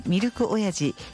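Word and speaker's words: ミルク082: 元酪農家で深谷市の村川紀弘さんが扮してミルク、ミルク 082 と書いてミルク親父の、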